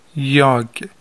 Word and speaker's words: jag: یاگ، یاگ یعنی من، JAG.